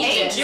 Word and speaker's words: aegis: Is it spelled like A-E-G-U-S? – Uh-huh. – Okay. – Well, how do you say that? – I thought it was Aegis. – That sounds more right. – Can I Google that really quick? – Yeah. – Aegis. – Oh, Aegis. You were right.